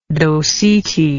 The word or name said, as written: docetae